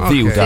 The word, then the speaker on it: Ceuta: Come se ci fosse il TH inglese, Ziuta. Okay. Ziuta. Con l'accento rigorosamente sulla prima sillaba, ragazzi.